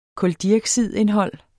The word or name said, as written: kuldioxidindhold